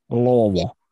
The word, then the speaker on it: Louisville: You have to say it like you have a golf ball in your mouth. – Louisville.